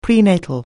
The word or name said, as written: prenatal